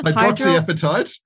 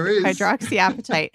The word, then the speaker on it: hydroxyapatite: Hydroxyapatite. I don't know. – Hydroxyapatite. – Thank you. There is an appetite in there. I knew it. Hydroxyapatite.